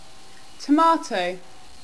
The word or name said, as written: tomato